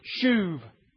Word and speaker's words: shuv: It begins with the word shuv. I guess in English you'd, you'd spell that S-H-U-V.